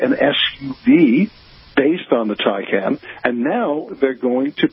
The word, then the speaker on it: Taycan: But you may recall that they introduced just last year the vehicle called the Taycan. T-A-Y-C-A-N is how they spell it, and it is, yeah, you might think of it sort of as an all-electric 911.